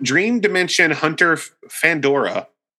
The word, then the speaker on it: Dream Dimension Hunter Fandora: So this came out Dream Dimension Hunter Fandora. – Mm-hmm. – I feel like I said it all wrong, but I don't know. I think I said it right though. – Yeah, you did.